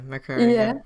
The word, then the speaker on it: Makerere: And if I'm not completely butchering the name, I want to say that the hospital was like Makerere Hospital. – Makerere. Yeah. like the university, yeah.